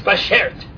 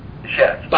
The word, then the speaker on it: bashert: Well, I think you're, you're, you're overextending it, but that is the right word. It's Bashert. Bashert. Bashert. Cut it down a bit. Bashert, yeah.